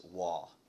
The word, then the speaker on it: waw: There's a Hebrew word called, I love this, the way you pronounce it, and correct me if I'm wrong, but this is the way I always hear it in my head when I read it, is waw. Is that right? Waw, W-A-W? – Depends on who you're talking to.